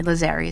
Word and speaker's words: Lazzari: You can also go to their Twitters at CT Mascaro, M-A-S-C-A-R-O, and at Bob_Lazzari. That's the Italian way. Lazzari is how you say it in English with the English accent.